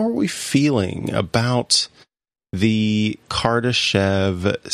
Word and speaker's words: Kardashev: And it's pronounced Kardashev.